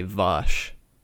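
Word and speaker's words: Vaush: I learned recently that I've been pronouncing Vouch's name wrong. – Honestly, keep fucking it up. – It's supposed to be Vosh.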